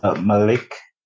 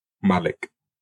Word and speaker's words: Malik: Well, it's Malik. It's kind of in between E and E. – Oh, Malik. – Sort of. – You made it worse. – I made it worse. No, because in Arabic. – You stress the A. That's why I say Malik. – Right. – Yeah, you kind of stress the A, but not forgetting to pronounce the I towards the N.